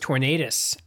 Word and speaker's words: Tornadus: I thought it was Tornadus. – Tornadus. – That's what I thought, because it's like a tornado.